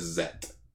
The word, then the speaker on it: Z: Well, I even like on the Japanese version of the Ultraman Z logo, in the middle of the Z, it actually says Zet. – Yeah. – So it's like, that's how you pronounce it. – Yeah, but I'm just going to call him Zed just for my peace of mind.